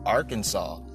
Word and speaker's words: Arkansas: It's not his Kansas, her Kansas, your Kansas, or my Kansas. It's Arkansas. – And it's not pronounced like Arkansas, which is what it's spelled like.